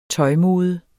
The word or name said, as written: tøjmode